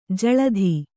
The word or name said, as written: ಜಲಧಿ